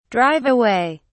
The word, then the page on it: drive away: British English